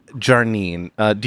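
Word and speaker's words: Jarneen: Jarnine. – I'm sorry? – Jarnine.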